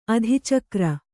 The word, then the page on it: ಆದಿಚಕ್ರಿ: Kannada